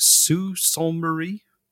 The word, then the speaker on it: Sault Ste. Marie: And then they were going to attack from Buffalo across the Niagara River and from Detroit to Windsor and from, how do you say it? Sault Ste. Marie? – Yeah, actually, that's pretty good.